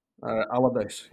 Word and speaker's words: Allardyce: I'm not even going to bother try pronouncing your last name. – Allardyce, yeah. – Allardyce, okay. – Yeah, but you couldn't do it in the accent, right? So don't bother. So, Jamie, what is your background?